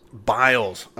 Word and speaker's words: Biles: Biles.